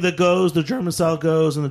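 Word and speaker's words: Gose: G-O-Z-E. – G-O-Z-E. – Yeah. Goes. Like steady as she goes.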